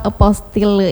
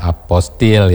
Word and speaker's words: apostille: Memang orang yang baru pertama kali melihat tulisan atau kata apostille ini pasti membacanya apostil ya, biasanya. Kalau mungkin ini serapan bahasa asing dan sebagainya. Nah, ini tapi sesungguhnya ini dari bahasa asing juga. Memang apostille ini dibacanya cukup aposti. – Aposti saja, nggak sampai tilenya. Aneh juga ya.